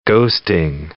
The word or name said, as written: ghosting